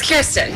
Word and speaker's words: Kirstin: It's Kirstin.